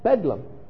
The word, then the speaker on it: Bedlam: And because of their accent, The Londoners pronounced it Bedlam.